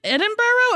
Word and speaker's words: Edinburgh: Is it Edinburgh? Am I saying that right? – Yeah.